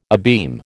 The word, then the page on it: abeam: American English